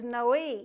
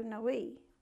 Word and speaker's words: Noe: Really, his name is Noe, if you pronounce it right from the Hebrew, Noe.